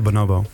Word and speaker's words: Bonobo: Van, is het Bonobo, denk je, Abdelkarim? Of Bonobo? Ik ben een Amsterdammer, hè, dus ik leg de klemtoon nog wel eens verkeerd. – Ik schaal het Bonobo.